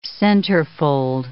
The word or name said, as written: centerfold